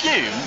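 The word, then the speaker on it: Huhne: Chris Hune. How do you pronounce it again? – Hune. – Just the Hune. – Definitively. – Hune makes you breathe more easily. – Definitively, the definitive pronunciation.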